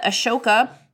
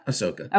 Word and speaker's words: Ahsoka: We know that Ahsoka was showing up in... Did I say it wrong? – You did. – Ahsoka? – Ahsoka.